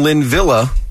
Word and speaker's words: Linvilla: It's spelled L-I-N-V-I-L-L-A. – It is. – That sounds Lynn Villa to me.